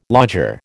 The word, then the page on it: lodger: American English